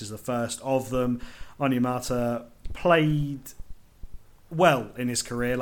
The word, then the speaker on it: Onyemata: Next up, David Onyemata, the Falcons, three-year, $35 million deal.